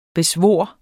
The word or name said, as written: BESVOR